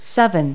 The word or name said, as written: seven